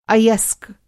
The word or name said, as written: अयस्क